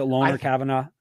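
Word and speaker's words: Lone'er Kavanaugh: Is it Loner Kavanaugh? – I think so. – It's Lone, L-O-N-E, and then an apostrophe E-R, Kavanaugh, right? – Sure, yeah.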